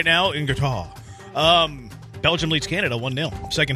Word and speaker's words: Qatar: The official pronunciation of my country is Qatar.